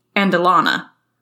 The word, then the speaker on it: Andelana: Well, couldn't find anywhere that would pronounce it for me, so we're going to pronounce it the way I'm reading it, which is the Andelana. – Andelana? – Yeah. A-N-D-E-L-A-N-A. – Andelana. Yeah, that sounds right.